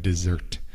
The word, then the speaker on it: dessert: So desert, desierto, just has one S, D-E-S-E-R-T. That's desierto. And then we've got dessert, postre. It sounds weird. You would think it would be like desert because there's the two S's, but we just say it like dessert, right? We almost say it with like a Z sound, Z. But it's spelled D-E-S-S-E-R-T.